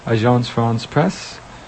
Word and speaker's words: Agence France Presse: So you actually pronounce it right, Agence France mm-hmm. Presse. I, I, am close. My brother probably pronounces it even better than I do because he actually speaks French.